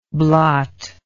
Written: blotty

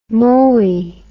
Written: mooi

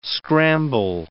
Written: scramble

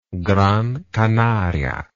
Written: Gran Canaria

